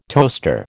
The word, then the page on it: toaster: American English